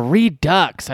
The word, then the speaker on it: Redux: How do you pronounce that when there's like the EU and the X? Redux? – Yeah, Redux. I like that.